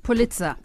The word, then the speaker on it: Pulitzer: Pulitzer.